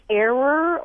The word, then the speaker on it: err: You would think it would be like error or errant. But no, the traditional pronunciation for a long time was err. And you know, William Safire once said that when enough of us say it wrong, we'll be right. And more of us are saying it as air, and I imagine that in another generation it will be air.